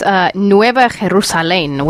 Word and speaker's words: Nueva Jerusalén: And this is in, it's in New Jerusalem, Mexico, but I imagine it's pronounced a little differently. – It's Nueva Jerusalén, which is the small little enclave in one of the in an area of Michoacán, which is a western state of Mexico.